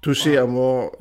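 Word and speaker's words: Touché Amoré: Heißt sie Touché Amour? – Touché Amour, ja, ja genau.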